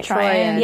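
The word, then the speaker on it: Troian: And also, Troyan, that's how you pronounce her name, right? – Troyan.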